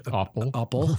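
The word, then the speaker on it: appel: For instance, there was this tavern on the edge of Fort Orange called the, how do you say apple in Dutch? – Oppel. – Very close.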